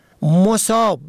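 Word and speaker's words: مُصاب: مصاب به ضم اول به معنای مصیبت زده، سختی دیده، مصیبت رسیده.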